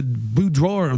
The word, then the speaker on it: boudoir: How's it pronounced? – Boudoir. – Boudoir. – Bedroom photos. – B-O-U-D-O-I-R. Yeah. It's French.